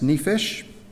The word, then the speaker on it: nephesh: And the Hebrew, the word which is used is a word called pronounced nephesh, and occurs over 700 times just in the Old Testament.